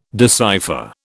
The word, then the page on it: decipher: British English